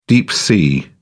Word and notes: deep-sea: British English

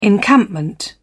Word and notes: encampment: British English

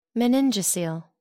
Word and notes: meningocele: American English

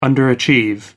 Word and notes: underachieve: American English

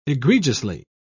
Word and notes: egregiously: British English

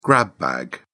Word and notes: grab bag: British English

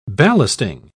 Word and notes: ballasting: British English

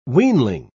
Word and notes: weanling: British English

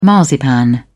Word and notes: marzipan: British English

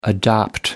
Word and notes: adopt: American English

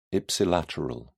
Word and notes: ipsilateral: British English

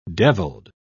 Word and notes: devilled: British English

